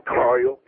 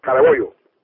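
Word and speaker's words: Carollo: It's not Carlo. It's Caraboyo. Caraboyo.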